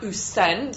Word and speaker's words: Ousen: I did know it was a Japanese word, and I think it's pronounced usen. So you put the emphasis on the second part of the word.